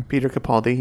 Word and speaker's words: Capaldi: Peter Capaldi, yeah.